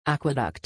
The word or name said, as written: aqueduct